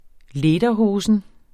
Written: lederhosen

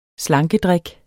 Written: slankedrik

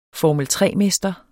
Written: formel

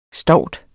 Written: stout

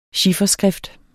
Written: chiffer

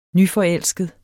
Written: nyforelsket

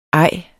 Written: ej

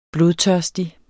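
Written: blodtørstig

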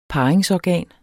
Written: parringsorgan

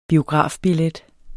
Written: biografbillet

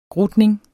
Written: grutning

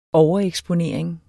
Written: overeksponering

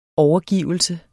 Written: overgivelse

